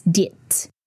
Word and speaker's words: det: It's D-E-T. if I were to literally pronounce it, it's diet. D-E-T. Ha, diet, so, S, A with the circle on top, or bra, B-R-A.